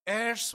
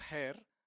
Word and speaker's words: heir: Along with the Son, he wants us to lift up because we are fellow heirs with Christ. You know what heir is? It's not hair. H is silent. Fellow heir. H-E-I-R.